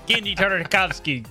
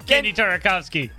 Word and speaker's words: Genndy Tartakovsky: Genndy Tartakovsky. Genndy Tartakovsky. Genndy Tartakovsky.